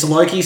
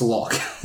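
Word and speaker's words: Loke: It's Loki spelled with an E, which I find that hurts. That hurts my soul. I hate that. – It is Loki. – It's Locke. It's Locke, but spelled wrong.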